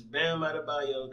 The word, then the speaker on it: Bam Adebayo: And then the backups is Bam Adebayo, that's how you say his name, Brandon Ingram, Jimmy Butler, Rudy Gobert, Donovan Mitchell, Kyle Lowry, and Chris Middleton.